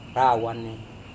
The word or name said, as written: rawane